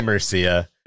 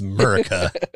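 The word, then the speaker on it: Merica: God damn it, it's Murica. Yeah, it would be Mercia. But it's spelled Murica. It's M-E-R-I-C-A. It's Murica.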